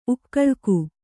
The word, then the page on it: ಉಕ್ಕಲಿಕೆ: ukkalike